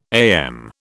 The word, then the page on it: AM: British English